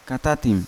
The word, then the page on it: katatim: Yawa